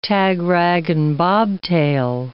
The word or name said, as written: tag, rag, and bobtail